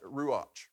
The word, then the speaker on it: ruach: So the word breath in the Hebrew, it's spelled R-U-A-C-H. This is really neat. And I look at you like ruach, right? Or something like that. But it's ruach. That's how they pronounce it, ruach.